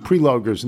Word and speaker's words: Preloger: Although you butchered Bob Preloger's name, it's pronounced like someone who has not yet cut down a tree.